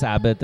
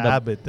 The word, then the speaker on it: Abbath: How do you pronounce it? For me, it's Abbath. It's not Abbath. – Because I can take Abbath.